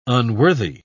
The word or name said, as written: unworthy